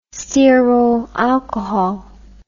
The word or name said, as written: stearyl alcohol